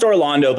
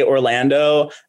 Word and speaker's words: Orlando: It's pronounced Orlando, but I won't get mad at you if you say Orlando, unless you've known me for like 5 years and you're still saying my name weird.